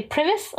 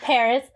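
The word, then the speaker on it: PVRIS: Or how I used to pronounce it, because it's P-V-R-I-R-I-S, so I would say Primus. – Primus. – But Paris.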